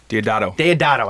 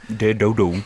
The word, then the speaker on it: Deodato: Deodato. – I always say his name wrong. – Deodato.